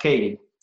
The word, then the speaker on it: Keaty: K-E-A-T-Y. It's spelled Keaty, pronounced Katie.